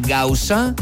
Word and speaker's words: Gausa: Venga, ahora mismo, o si no, cuando tengas un momento, abre la aplicación de Spotify, pon mi nombre en el buscador, David Gausa, que se escribe G-A-U-S-A, te aparece la imagen de la canela fina playlist, y entonces ya sabes lo que tienes que hacer, ¿no?